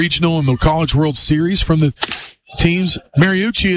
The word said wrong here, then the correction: Marucci: is the one that they have. Is that, am I saying that right? I can't remember. – Marucci, yes.